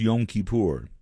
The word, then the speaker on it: Yom Kippur: I believe it is pronounced Yom Kippur.